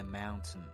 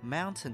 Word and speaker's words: mountain: 然后前面这个单词 foot 在四级当中呢确实考过脚的意思，但指的并不是你那双汗脚，而考察的含义是底部山脚下， At the foot of the mountain. Mountain 的意思是山，这个大家都知道，但是它的发音会有一些变化，就是英音呢可能会读作 mountain，然后呢美音的话这个 ten 它就不会读出来了，它用鼻音来读，就是 mountain。你可能学不会这个发音，但在听力里面你要适应这个发音 mountain mountain。 OK，下一个单词 mine M-I-N-E mine。